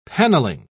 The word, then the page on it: paneling: British English